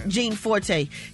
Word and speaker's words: Gianforte: Gianforte.